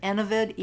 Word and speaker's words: Enovid: She gets the funding, and whammo, by the end of the 1950s, the scientists had created the first birth control pill called, I pronounce it Enovid, E-N-O-V-I-D, Enovid.